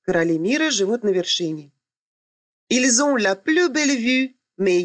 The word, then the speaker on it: ils ont: При произношении ils ont делается liaison, то есть связка, так как слово заканчивается на согласную, а следующее начинается с гласной.